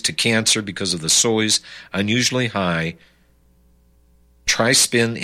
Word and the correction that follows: trypsin: inhibitors, or trypsin inhibitors, pronounced correctly.